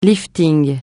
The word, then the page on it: lifting: francais